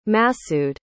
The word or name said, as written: Masoud